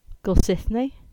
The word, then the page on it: Goldsithney: English